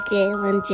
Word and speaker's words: Jalen: My name is Jalen Johnson.